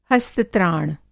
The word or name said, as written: हस्त-त्राण